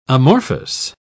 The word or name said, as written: amorphous